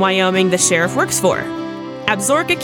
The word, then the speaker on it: Absaroka: It's pronounced a little weird. You would expect it to be said Absaroka, but it's actually Absaroka, and the actual word is in the crow tongue and means children of the long-beaked bird.